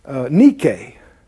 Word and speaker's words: nike: The word victory, the Greek word, is nike. And it's also the word that the popular athletic shoe company, Nike, Nike, well, that's how they're pronouncing it.